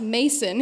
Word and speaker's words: Macon: hey, you mispronounced Macon. It's supposed to sound like bacon. And I said, oh no, let me introduce you to a few country songs off the internet. It's pronounced Mason here.